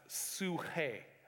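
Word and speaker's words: suche (ψυχή): And the word is pronounced suche. which I worked really hard on getting that right.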